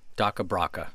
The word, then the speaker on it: DakhaBrakha: Daka Braka, spell it. – D-A-K-H-A-B-R-A-K-H-A. – Daka Braka.